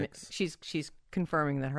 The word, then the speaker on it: Pics: It's spelled with a C, right? Not with an X. – P-I-C-S is how you spell Jane's last name, Pix. – Not with an X. – P-I-C-S.